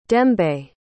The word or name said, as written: dembe